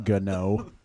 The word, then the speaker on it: Gano: It's pronounced Gano. – I know.